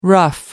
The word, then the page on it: ruff: American English